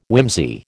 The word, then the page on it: whimsy: American English